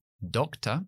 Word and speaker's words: doctor: O, por ejemplo, doctor, doctor. La schwa está al final. Acentúo. O, doctor, esa primera sílaba, doc, se acentúa, y luego a uh, al final, t no se acentúa.